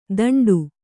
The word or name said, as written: ತಂಡು